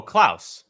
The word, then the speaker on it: Klaus: Klaus? – Oh, Klaus.